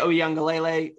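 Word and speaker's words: Uiagalelei: DJ Oyunglele, who is the quarterback at Clemson, he got pulled. – Hang on. That was an excellent pronunciation.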